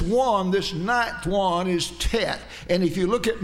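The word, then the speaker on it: Tet (ט): We'd say T-E-T, or we'd call it Tet.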